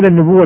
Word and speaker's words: النبوة: النبوة، كلمة نبوة تنطق على وجهين، يقال النبوءة والنبوة.